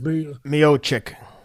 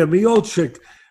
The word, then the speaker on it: Miocic: Miocic. – Yeah, Miocic.